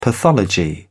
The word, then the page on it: pathology: British English